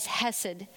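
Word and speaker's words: hesed (חסד): The Hebrew word for steadfast love is hesed, which is spelled C-H-E-S-E-D.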